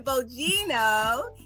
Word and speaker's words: Bogino: Diane Bogino.